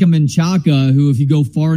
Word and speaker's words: Manchaca: I've never appreciated that pronunciation of things. Like, I understand where the Kevin Dunns of the world are coming from with Miller instead of Mueller. Like, that was the pronunciation back in the day. And Manchac kind of is too, but that's like more of like a rednecky pronunciation of a completely different word that's not anywhere close.